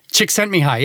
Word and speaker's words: Csikszentmihalyi: This was sort of invented and explored by a famous psychologist named Csikszentmihalyi. – Is that how it's pronounced? – Csikszentmihalyi, yeah.